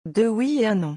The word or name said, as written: deux oui et un non